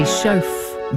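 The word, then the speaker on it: Shauf: That is Andy Schauf. – I'm pretty sure that's how you say it. S-H-A-U-F. I'm pretty sure that's right. I'm sure you'll correct me if I'm wrong.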